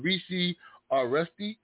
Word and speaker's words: Reecy Aresty: Mr. Reecy Aresty, if I'm saying it right? – Yeah, that's right.